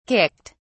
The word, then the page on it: kicked: British English